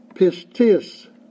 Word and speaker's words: pistis: And the Greek word, this won't mean anything to you probably, but the Greek word is generally the word pistis. It's spelled P-I-S-T-I-S, just like it sounds.